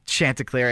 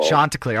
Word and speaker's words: Chanticleer: First off, what is a Chanticleer? And second off, what is the short name? How do you say the shortened version of this nickname? – Sure. It's Chanticleer, first of all. – Chanticleer.